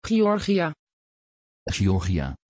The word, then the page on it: Giorgia: Dutch